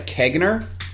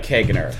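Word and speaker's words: caganer: I believe it's pronounced a Kegner. Kegner.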